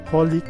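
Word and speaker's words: xxxHOLiC: Pues esta otra serie ya de los 2000, Holic, así es como lo pronuncian, aunque es XXX Holic,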